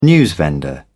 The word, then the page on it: newsvendor: British English